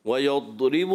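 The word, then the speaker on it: ويضرب: ویضرب اللہ, یہ کلکلہ ویضرب اللہ, تو کلکلہ نہیں کرنا. اس کا تلفظ دوت کا لمبا ہے, صفت استطالت کی وجہ سے جب آپ وہاں سے زبان ہٹاتے ہیں تو ایسا محسوس ہوگا کہ کلکلہ ہوا ہے.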